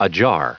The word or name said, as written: ajar